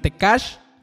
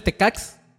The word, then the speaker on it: Tekax: Tecash es, se los voy a deletrar, T-E-K-A-X. La X en maya, porque es, un, es en Mérida, la X se pronuncia como si fuera SH en maya. Entonces, por eso es Tecash. Realmente, si lo leyeras en, como la lengua español es Tecax.